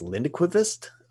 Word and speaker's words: Lindquist: And it is called Let the Right One In by John, I think it's Lindquist. It's a Swedish pronunciation.